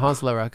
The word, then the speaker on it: Honsla Rakh: Speaking of appreciation, you probably are going to receive a lot of it from wardrobe pirates for Honsla Rakh. What was it? How do I say it? – You said it right, yeah. Honsla Rakh.